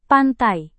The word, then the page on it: pantai: Indonesian